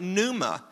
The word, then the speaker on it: pneuma: In the same way, in the New Testament, the Greek word is pneuma. The P is silent, pneuma.